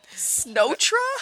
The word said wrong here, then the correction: Snotra: Excuse you, it's pronounced Snotra? – Snotra.